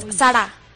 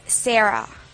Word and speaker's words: Zara: No es Sara, es Sarah.